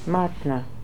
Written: Martna

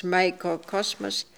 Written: Microcosmos

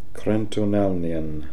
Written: Krentonalnian

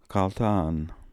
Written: Kaltaan

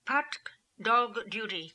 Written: Partkdolg duty

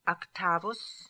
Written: actavus

